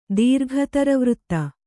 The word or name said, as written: ದೀರ್ಘತರವೃತ್ತ